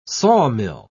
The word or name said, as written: sawmill